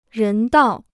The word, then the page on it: 人道: rén dào